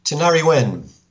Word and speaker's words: Tinariwen: And the name of the band, I'm not really sure I'm pronouncing it right, but Tinariwen? – Tinariwen. – Tinariwen. – Yeah, well, that's how they pronounce it here in France. They've worked with some American musicians who might pronounce it differently.